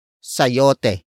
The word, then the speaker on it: sayote: And that is sayote. Sayote. Sayote. Sayote is spelled as S-A-Y-O-T-E.